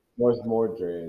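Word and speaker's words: Morsmordre: Mors Mortar. Or Mors Mord. I don't know how to pronounce it. – Mors Mordre is how I pronounce it. Mors Mordre. There we go.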